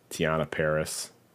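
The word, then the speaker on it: Teyonah Parris: Same cast mostly: Elizabeth Olsen, Paul Bettany, Catherine Hahn, Tiana Paris. Is that how you pronounce it? – Yeah, I think so. Yeah, you nailed it.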